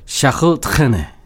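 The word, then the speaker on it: Charles Trenet: 그 샹송 역사에서 아주 중요한 아티스트 샤를 트레네. – 네. 예, 샤를 트레네. 오리지널 발음으로 하면은 네. 샤흐 트레네. 네, 그렇죠.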